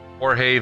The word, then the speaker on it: Jorge: I'm on Facebook at Jorge Vigija. That's spelled J-O-R-G-E. Last name spelled V as in Victor. E-G-U-I-L-L-A.